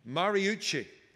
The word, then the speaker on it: Mariucci: Steve Marucci, former head coach. Am I pronouncing that right? Mariucci. All right.